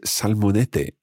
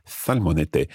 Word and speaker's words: salmonete: But in the recording, you'll hear the fishmonger pronouncing it as salmonete, and that's where the th sound, the th sound, if you like, is replacing S, as it happens in some parts of Spain.